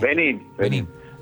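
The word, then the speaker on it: Benín: Aparte, un partido tan difícil como con Benis, calculo que lo querrá tener. – Benin. – Con acento en la I. – Benin.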